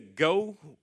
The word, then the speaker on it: Geaux: you head to Go Vote. And there's a Go Vote app. It's spelled G-E-A-U-X, vote, the Louisiana way.